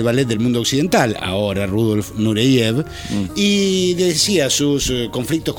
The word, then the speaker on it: Rudolf: Claro, Rudolf, que es como se pronunciaba su nombre, Rudolf, dice, bueno, estoy en París bailando.